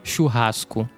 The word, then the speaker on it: churrasco: Churrasco. – Churrasco, come si scrive? – C-H-U-R-R-A-S-C.